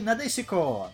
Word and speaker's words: Надесико: А, собственно, да, мы рассмотрим сегодня, так в английском переводе он звучит как Marshall's Successor Nadesica Ренадесика, как правильно произносится по оригиналу. А в оригинале он звучит как Кудой Сенкай Надесико.